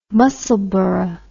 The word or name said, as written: Musselburgh